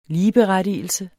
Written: ligeberettigelse

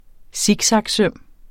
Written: siksaksøm

